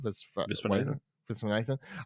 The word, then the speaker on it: Viswanathan: Viswanathan.